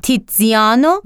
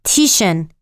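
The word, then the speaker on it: Tiziano: تیتزیانو و چلیو معروف به تیتزیان در ایتالیایی، تیشن انگلیسی و تیسیان در تمام منابع فارسی.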